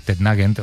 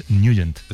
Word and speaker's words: Nugent: Hombre, siempre lo hemos llamado en el barrio Tednagent o Tednagent. – Claro, yo era de Tednagent. – Pero la pronunciación correcta es Nugent.